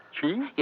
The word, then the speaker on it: Chih: Qi? – Yes. It is spelled C-H-I-H, Qi.